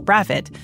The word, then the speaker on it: Brathwaite: In the British West Indies, the surname Brathwaite, which is often pronounced Braffit, is a very common name.